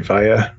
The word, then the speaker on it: Vaya: At the Drive-In comes out with a band called Vaya, I'm saying it right, Vaya, V-A-Y-A.